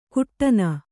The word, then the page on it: ಕುಟ್ಟನ: kuṭṭana